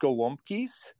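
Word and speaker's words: gołąbki: Let's turn now, to cabbage rolls, gwumkis, we called them. I don't know if we pronounced it right, the Polish growing up. Can you tell us about these Polish cabbage rolls? – Yes, you nailed it. It's pronounced gwumkis.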